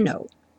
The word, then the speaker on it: know: O K do know não é nunca pronunciado, vocês já sabem, né? Então, I use to know.